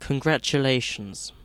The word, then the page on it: congratulations: British English